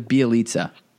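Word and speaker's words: Bjelica: Your mispronounced name segment that we were just about to get into was not hardy enough, but that is quite possibly a Hall of Fame mispronunciation of Bajelica instead of Bielitsa.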